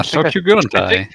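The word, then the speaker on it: Sokyu Gurentai: Yeah, Sokyo Gurantai. – I think I pronounced that correctly. Some people call it Soki Yogurt, and I don't blame them.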